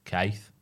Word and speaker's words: Keith: In Sunderland, Keith rhymes with me. – Keith.